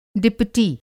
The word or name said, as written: डिपुटी